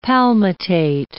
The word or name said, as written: palmitate